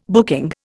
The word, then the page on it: booking: Indian